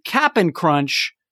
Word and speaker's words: Cap'n Crunch: But now in the sweet tweet, he's saying, actually, no, I think I'm more on the side that it should be Cap'n Crunch, not Captain Crunch.